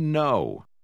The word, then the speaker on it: know: Lembre-se de que a letra K não se pronuncia. – We know.